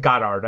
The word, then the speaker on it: Goddard: Sorry, you sure it's not Goddard? – What did I say, Goddard? – You said Goodard. – Yeah, I think it's probably pronounced Goddard. I think you're correct. It's G-O-D-D-A-R-D.